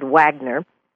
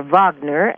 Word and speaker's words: Wagner: Now, he absolutely loved Richard Wagner, or Wagner, as it is properly pronounced, and he would just do anything and everything to support this brilliant composer.